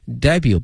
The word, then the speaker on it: debut: 英文有一个专门词叫 debut，D-E-B-U-T，但同学们注意了，这个后面这个 T 呢是不发音的，是 make something 或 someone's debut，表示首次亮相、首次登场的意思哈。